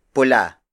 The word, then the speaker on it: pula: Word for word it's puting tupa puting tupa puting tupa again the tagalog word for white is puti the third native color is pula pula pula pula is spelled as p u l a it means red the color of blood, the heart, and most types of apples.